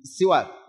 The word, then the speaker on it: السواك: بالسواك، السواك الكل يعرف، لكن من باب المعرفة العلمية شرعا ولغة، فالسواك هكذا بهذا النطق، السواك بكسر السين في اللغة يطلق على الفعل، سواك يعني الفعل يعني التسوق، ويطلق على الآلة التي تسوك بها، فيقال هذا سواك.